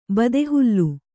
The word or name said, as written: ಬದೆಹುಲ್ಲು